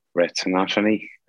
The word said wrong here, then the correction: retinopathy: I think it's called. – Retinopathy.